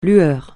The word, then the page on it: lueur: francais